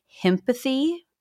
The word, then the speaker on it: himpathy: And I don't remember which one it is in which she coined the term himpathy, as it rhymes with sympathy, to describe what is allegedly a societal impulse en masse to sympathize with powerful men who perpetrate bad acts against women.